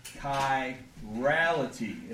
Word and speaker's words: chirality: And the word is chirality. If I would spell it correctly, that would be good. Chirality. C-H-I-R-A-L-I-T-Y.